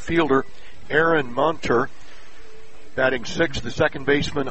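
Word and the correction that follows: Moenter: correct pronunciation, more like Munter, as if it were a U, M-O-E-N-T-E-R.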